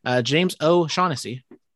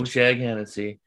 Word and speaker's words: O'Shaughnessy: So James O'Shaughnessy or Oshak Hennessy, whichever way you want to pronounce it. – Oshak Hennessy.